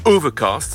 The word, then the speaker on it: OVRKST: Voici maintenant la voix d'un jeune rappeur de Oakland, c'est une ville qui était tout près de San Francisco, qui signe Overcast, ça s'écrit O-V-R-K-S-T.